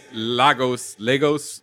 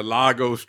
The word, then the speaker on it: Lagos: The Lagos paper towel. – Lagos. Don't pronounce it. Don't do that.